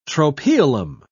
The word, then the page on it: tropaeolum: British English